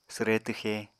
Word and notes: swre twxe: apatani

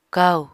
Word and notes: kaù: apatani

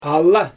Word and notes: ala: Xyzyl

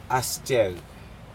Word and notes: asjec: Aneityum

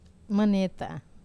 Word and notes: maneta: Wamesa

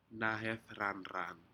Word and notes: nahedranran: Aneityum

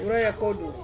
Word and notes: uraia kodu: Ansus

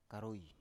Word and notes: karoi: Biak

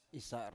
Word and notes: isar: Biak

